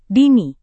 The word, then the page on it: dini: indonesian